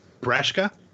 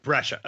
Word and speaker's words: Brescia: And now that she is currently playing Heidi Hansen on Broadway, how do you pronounce Lisa's last name? – Brescia. – Okay, one at a time, because you both are saying it and I can't hear either of you. What is it? – Brescia.